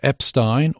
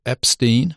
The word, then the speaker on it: Epstein: Sister Aimee, The Life of Aimee Semple McPherson by Daniel Mark Epstein, or if you're an American and mispronounce it, Epstein.